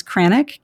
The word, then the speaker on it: Kranack: Last name is Kranack, K R A N A C K dot com.